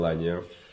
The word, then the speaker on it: Lagnaux: Daniel Lagnaux, as you correctly pronounced it.